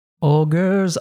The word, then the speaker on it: ogre: But I find interesting the pronunciation of ogre because we have a G-R-E here. We say ogre, right? – Ogre. Yeah. – Ogre. – Not the case there, like he did a normal R, but I'm pretty sure in other ones he would do like a tap R, like a r-r-r.